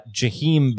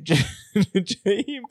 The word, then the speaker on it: Jaheim: All right, Dave, I did 8 seconds of YouTube research, and I've got Jaheim Bell. – Jaheim Bell. – You were making the J a Y, which is interesting. That's a bold move there, not just making it a J. That's okay. Jaheim Bell.